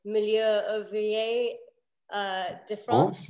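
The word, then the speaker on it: Meilleur Ouvrier de France: And both your father and Denis Ruffel at the Patisserie have been awarded Milieu Ouvrier de France.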